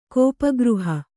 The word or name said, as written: ಕೋಪಗೃಹ